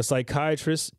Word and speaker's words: psychiatrist: Why can't you hear a psychiatrist using the bathroom? – Silent treatment. – How is that a dad joke? – But she's in the right ballpark because the P is silent. When you spell psychiatrist...